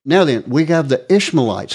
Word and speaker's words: Ishmaelites: Ishmaelites? – Yeah.